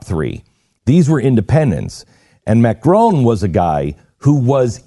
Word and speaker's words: Macron: Macron? – Macron, yeah.